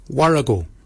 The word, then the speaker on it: worragol: Barken is spelled B-A-G-I-N-Y. Barken. Barken is your beard. The upper mouth, we say warrigal. Warrigal. Warrigal is spelled W-O-R-R-A-G-O-L. Warrigal. Warrigal is your upper mouth. For your nose, we say mol. Mol is your nose. Mol is spelled M-O-O-L-Y, Moll.